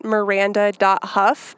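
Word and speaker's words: Miranda Hough: It actually looks like Hogue. I keep botching her name horribly. I apologize. – It's Miranda.Hough.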